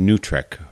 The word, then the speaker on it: NWTRCC: To recap, last week we spoke with Lincoln Rice, staff person for NWTRCC, the acronym for the National War Tax Resistance Coordinating Committee, often pronounced NUTRIC,